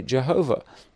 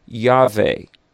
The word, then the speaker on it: Yahweh: Sometimes people say Jehovah, but there's no J sound in the Hebrew language. And so basically, and there weren't vowels in that Exodus 3:14 name. So if you pronounce the four Hebrew consonants, you sort of get Yahweh.